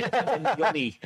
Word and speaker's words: Nyoni: And then a little shout out to Trey Anonhihi in I've said that wrong. What's his name? Sorry, Nyoni, uh, who uh, we gave a shout out on the Q and A, didn't we, earlier this mm.